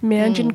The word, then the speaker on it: Meanjin: and pardon me if I'm pronouncing this incorrectly, is it the Meanjin Quarterly? – Meanjin Quarterly, called The End of the World as We Know It.